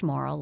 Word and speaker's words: Morrill: And it's pronounced Morrill, like not immoral, but Morrill. But it's M-O-R-R-I-L-L.